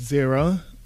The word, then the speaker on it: Xiro: So I just finished my first album called Zero. It's spelled X-I-R-O, and that was going really well.